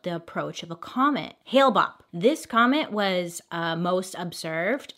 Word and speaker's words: Hale-Bopp: Around the same time, the group had learned about the approach of a comet named Hale-Bopp. Is that how you say it? I feel like that's not right. – Hale-Bopp.